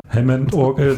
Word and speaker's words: Hammond: Du spielst ja nicht nur Harmon-Orgel, habe ich das jetzt richtig ausgesprochen? – Gar nicht, das war alles falsch. – Ja, okay. Am besten nochmal. Aber du spielst ja nicht nur Hammond-Orgel, sondern auch Gitarre und so.